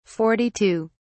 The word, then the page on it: 42: British English